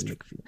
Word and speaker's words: McFeely: Yeah, I'm like, oh, I was thinking Mr. Feely, not Mr. McFeely. Yeah. Missed a syllable.